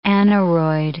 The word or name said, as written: aneroid